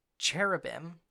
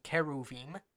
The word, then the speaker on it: cherubim: placed cherubim, or in the Hebrew pronunciation, keruvim, uh, at the east of the garden, and a big flaming sword.